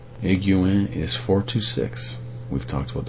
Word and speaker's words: Higuain: H-I-G-U-A-I-N, Higuain. I guess the H is silent, and the G-U-A-I-N, 3 vowels in a row, is we don't normally run across that particular combination in English.